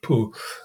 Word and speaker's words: Bon: or it's actually spelled Bon, but it's apparently pronounced Pu,